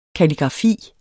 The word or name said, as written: kalligrafi